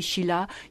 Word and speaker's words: Sheila: S-H-E-I-L-A, Schiller.